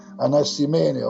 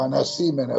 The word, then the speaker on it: Anassimene: in Grecia, Anassimene o Anassimene, a secondo dell'accento che vogliamo usare,